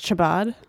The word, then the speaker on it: Chabad: It's pronounced Chabad.